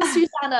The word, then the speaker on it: Susanne: And your name, I didn't realize your pronunciation is Susanne as well. I'm Susanne as well. It's so funny. – It's so weird always with the English speaking people, they can't say the E at the end.